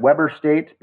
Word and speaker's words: Weber: And is that Weber State where the it looks like Weber State, but it's actually pronounced Weber State?